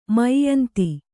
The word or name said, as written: ಮೈಯಂತಿ